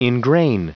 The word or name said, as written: ingrain